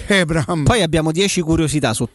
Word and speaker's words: Abraham: The correct way to say my name is Tammy Abraham.